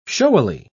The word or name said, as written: showily